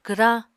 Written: kwrañ

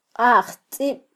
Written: qajtzib

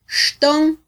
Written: xton

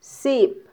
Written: sip

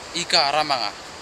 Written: ikaramanga

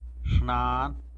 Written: xnan

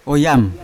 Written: oyam

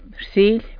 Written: rsily

